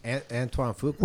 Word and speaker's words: Antoine Fuqua: The film is directed by Antoni Fugaga. Can't say that right. It is Olympus Has Fallen. – Antoine Fuqua.